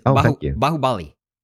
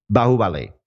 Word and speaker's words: Bahubali: By the way, the way to pronounce it, as I learned, is Bahubali. – Bahubali.